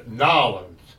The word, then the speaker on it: New Orleans: You were filming down in New Orleans? – No, we were New Orleans? – Not. You're going to pronounce it, it's Nolens.